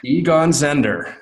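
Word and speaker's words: Egon Zehnder: She's a senior advisor of Eggenzender. Did I say that right? – Egon Zender, which is an executive recruiting group and doing a phenomenal job there.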